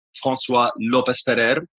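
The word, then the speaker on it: François Lopez Ferrer: My name is Francois Lopez Ferrer. You need the two different accents to pronounce that, French and Spanish.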